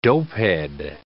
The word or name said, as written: dopehead